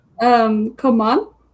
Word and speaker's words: Coman: Coman. – Yeah, yeah. Kingsley Coman. That's a good one. – You had the pronunciation right. That was good.